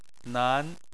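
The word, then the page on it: nan: tone 2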